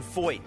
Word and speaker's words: Feucht: I'm talking to Sean Feucht. Can you spell it? No one can. But I can pronounce it. Feucht. F-E-U-C-H-T.